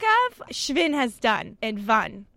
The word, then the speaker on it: Schwinn: Schwinn rhymes with win, just like Iota, Iota, Iota rhymes with nothing, because there are no competitors.